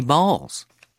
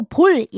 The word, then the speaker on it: ball: My balls. – My balls. 그래요. 자, 공 하나 있을 때는 a ball. A ball. 그렇죠? B-A-L-L 이런데 이걸 보고 볼 이렇게 발음하지 마시고요. Ball. Ball. 이렇게 하면 돼요.